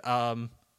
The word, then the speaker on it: Otunga: There have been so many wrestlers jumping to commentary in the past handful of years with Tunga, with Otunga. I don't know why I cut off that first syllable.